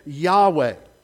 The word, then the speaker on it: Yahweh: How do you pronounce that? We don't know. I can't pronounce it without vowels. So some copyists over the years inserted vowels so that we could say it. So we say it Yahweh.